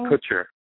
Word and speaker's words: Kutcher: Kutcher.